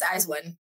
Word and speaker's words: IZ*ONE: It's IZ*ONE.